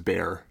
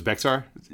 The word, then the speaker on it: Bexar: It took me a long time to learn that was Bear. – Did you think it was Bexar? – You know, there's an X in the middle of it. Like, it's not... Anyway. – Well, okay. You can pronounce it Bejar if you want.